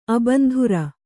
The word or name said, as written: ಅಬಂಧುರ